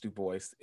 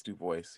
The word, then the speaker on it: Du Bois: It's Du Bois. It's Du Bois. Yeah, he, within a statement, he clarified the pronunciation.